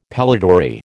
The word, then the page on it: pellitory: American English